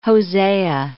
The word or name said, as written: Hosea